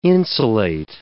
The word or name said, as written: insulate